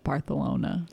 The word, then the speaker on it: Barcelona: If you're ever having a bad day, just remember that people in Barcelona pronounce it Barthelona.